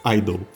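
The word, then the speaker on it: (G)I-DLE: Vamos, vamos começar logo pela Idol. – Que a gente tem que lembrar que não fala o G. – É... o G é mudo. – O G é fantasma.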